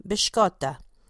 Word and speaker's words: biscotti: What they all have in common is that they are known as biscotti, and they are delicious. Most Italians, including myself and my family, pronounce them biscotta.